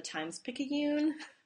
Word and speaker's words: Times Picayune: the articles that came out of the Times Picayune.